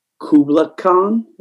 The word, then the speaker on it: Kubla Khan: It was us, Kubla Khan, if I'm pronouncing that right.